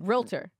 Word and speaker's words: Realtor: Yeah, it's not two syllables, it's like one. – Realtor.